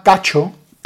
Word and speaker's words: Kcho: Hay un artista en Cuba que se llama Cacho, se escribe K-C-H-O,